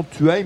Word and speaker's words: Thueyts: On prononce son nom Tueil, mais ça s'écrit T-H-U-E-Y-T-S.